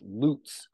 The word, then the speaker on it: Lutz: Oh, do you know my last name is actually pronounced Lutz. – Lutz. – I've gotten that since I was in third grade. And there are actually a lot of people out there who would pronounce it Lutz, but it's just, it's the long U.